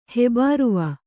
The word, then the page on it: ಹೆಬಾರುವ: Kannada